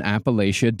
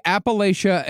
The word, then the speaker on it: Appalachia: The way, and by the way, Appalachia and Appalachia are both accepted pronunciations. Usually, okay. If you're more northern, you'll say Appalachia.